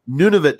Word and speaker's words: Nunavut: Nunavut Tidy from.